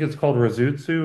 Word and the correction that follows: Rusutsu: or let me make sure I'm pronouncing it even anywhere here, Rusutsu.